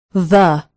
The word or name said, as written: the